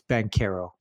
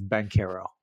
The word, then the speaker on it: Banchero: It's Banchero. We've both been saying it wrong. – Is it Banchero? No way. – Banchero. According to Basketball Reference, it's Banchero.